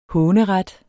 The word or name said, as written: håneret